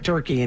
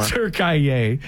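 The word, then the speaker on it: Türkiye: Literally, there was like a split second left in, how do you say it, Turkiye? It's not Turkey anymore. – Turkiye.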